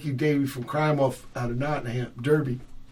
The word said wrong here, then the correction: Derby: Although I think they pronounce it Darby, huh? Like Darby Crash. – They do. – Yeah, they don't say Derby.